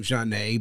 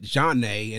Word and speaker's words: Zhané: And they came up with the idea of the name Jah-Nay by taking the beginning of first of their names and using the French pronunciation of their name. So they made it Jah-Nay and then added a Z for a little bit of flavor.